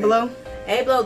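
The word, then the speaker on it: Abloh: Ablo, the guy who founded Off-White, mm-hmm.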